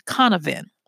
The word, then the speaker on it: Cynefin: and it's called Canavan. And it's spelled CYNEFIN.